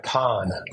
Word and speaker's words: Cannes: Yeah, it's just sort of like Con, C-O-N is what way you pronounce.